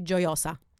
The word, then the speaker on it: Gioia: The pronunciation is actually Gioia.